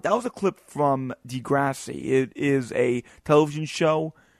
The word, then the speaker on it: Degrassi: I believe I finally said it right, so I want to give myself a tap on the shoulder. I always said Degrassi, but read Degrassi.